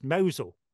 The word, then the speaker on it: Mousehole: So we moved to this village, which is spelled Mousehole and pronounced Mosel.